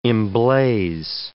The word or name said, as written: emblaze